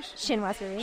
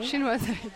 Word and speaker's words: Chinoiserie: Chinoiserie. – Chinoiserie, thank you.